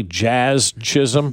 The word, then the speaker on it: Jazz Chisholm: And then Jazz Chisholm got kicked out of the game, right? Did I say that properly? Jazz Chisholm? – Mm-hmm. You gotta say it slow.